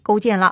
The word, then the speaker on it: 勾践: 勾践啊，勾心斗角的勾，实践的践，左传中勾写成句号的句，说起来呢是个多音字啊，在此处呢应该也是读勾的，或许呢这个也是有争议的，反正呢咱就按照传统历史课本啊，这一位呢就念成勾践了。